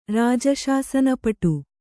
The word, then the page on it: ರಾಜಶಾಸನಪಟು: rāja śasana paṭu